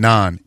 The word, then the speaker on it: naan: Somebody says it's spelled N-A-A-N, non N-A-A-N.